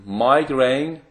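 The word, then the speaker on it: migraine: مایگرین اسم میگرن تلفظ صحیحشه، فارسی هم نیست.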